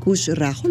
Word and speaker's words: Khushraho: O sea, eh, este concepto de cerveza artesanal con causa, eh, Kush Rajo, ¿lo dije bien? Sí.